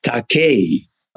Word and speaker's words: Takei: And I said, "Well, what uh, how it should re- truly be pronounced is Takei."